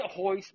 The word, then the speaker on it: Royce: But they say Hoyce, but it's actually spelled with an R.